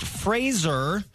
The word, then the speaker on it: Fraser: Brendan Fraser says we've all been saying his name wrong. It's actually pronounced Fraser, which kind of rhymes with razor.